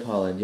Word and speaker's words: Haaland: It is Deb Haaland, right? Is that how you say it? – Yeah, Deb Haaland, yep.